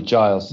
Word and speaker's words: Giles: And correct me, by the way, if I'm wrong, your name, is it a hard G on the front or is Giles right? – It's a hard G, Giles.